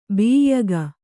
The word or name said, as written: ಬಿಯ್ಯಗ